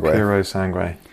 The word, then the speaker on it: Purosangue: I'm sure it's supposed to be pronounced with an Italian accent, but... – Puro Sangue. – That's what people are going to say, isn't it? – To me, it sounds French. Puro Sang. That's... – Yeah. – That's how... Yeah, but it clearly isn't, because it's an Italian car. – Yeah, the Italians pronounce the way on the end.